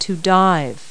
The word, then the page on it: dive: verb